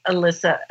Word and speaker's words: Alisa: So tell me, Alisa, I'm saying that right.